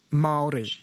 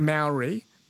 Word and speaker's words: Maori: It's M-A-O-R-I, but it's pronounced Maori, not Maori. Maori. – Like a Mao sound. – Because the A in Maori is just said that way, not Mao.